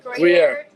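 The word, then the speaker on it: Gruyère: So this is the how do you say that? – Gruyere cheese.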